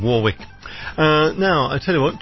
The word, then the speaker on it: Warwick: We say, of course, over on the other side of the pond, we say Warwick. Dionne Warwick. Because we do have a town which is spelled exactly the same, W-A-R-W-I-C-K, and we call it Warwick.